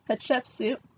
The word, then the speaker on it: Hatshepsut: So today we're going to learn about the Egyptian pharaoh Hatshepsut. – Hatshepsut? – Hatshepsut.